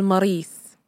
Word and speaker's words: Maurice: Herbert's always liked the name Maurice, especially the French pronunciation with the accent on the last syllable. – Ah, il s'appelle Maurice.